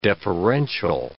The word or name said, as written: deferential